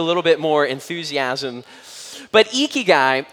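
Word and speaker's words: ikigai (生き甲斐): There is this Japanese word, this Japanese concept that's called ikigai, ikigai.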